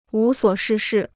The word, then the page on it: 无所事事: wú suǒ shì shì